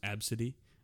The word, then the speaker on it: Abcde: It was ABCDE. So she spelt it. And someone laughed at her, and the kid, it's pronounced Absidy.